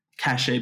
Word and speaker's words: cachet: Cachet by Mache, if you don't know how to pronounce it.